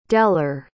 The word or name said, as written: dellar